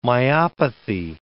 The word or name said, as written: myopathy